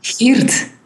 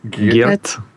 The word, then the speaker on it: Geert: So how do you say again? Sorry. – Geert. – Geert. – The G and the R in the Dutch or in Flemish is rather hard for English speakers.